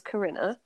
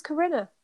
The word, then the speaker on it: Corinna: My real name is Corinna, but that's actually rhymes with dinner. – Rhymes with dinner, winner, sinner, nothing good.